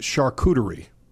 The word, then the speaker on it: charcuterie: It's called Ask Perrin, but I think it's pronounced charcuterie. That's how it is pronounced. Charcuterie.